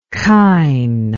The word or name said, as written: kain